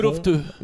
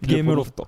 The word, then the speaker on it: Gameloft: Alors Gameloft Japon. – Gameloft. – Gameloft. – Je sais pas si c'est comme ça que vous le prononcez. – Gameloft.